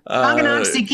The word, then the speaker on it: Tonganoxie: Tonganoxie, Kansas.